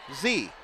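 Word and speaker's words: Xu: He is a junior forward, Michael Z, according to Todd Lancaster. I don't know if that's official or a guess, but it's spelled X-U, but we'll go with Z.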